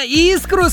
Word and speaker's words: искру: Эх, а сначала-то искру сказали, Елисей. С пятерочкой по русскому языку. Давайте разбираться. Согласно литературной норме русского языка, ударение в данном слове всегда падает только на первый слог. Поэтому правильно говорить искру, Елисей.